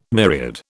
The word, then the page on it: myriad: British English